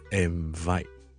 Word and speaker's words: invite: 如果你听得好仔细听住咧，invite 就会懒咗嘅，就唔会读成。 in n 音呢个位 invite 而系读成 im，好似咧嗰个鼻音咧系用下唇同埋上面嘅门牙挤埋一齐读，而唔系咧脷尖同埋 in 本身个位。